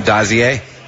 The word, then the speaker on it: Dozier: Dozier. Dozier, it's actually how you pronounce it, has to hit 23 home runs at least.